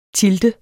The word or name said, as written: tilde